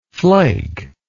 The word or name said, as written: flaker